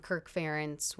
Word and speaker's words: Kirk Ferentz: I think that everyone has been very, you know, from Kirk Ferentz when I was in college, mm-hmm.